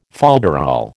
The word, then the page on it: folderol: American English